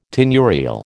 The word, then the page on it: tenurial: American English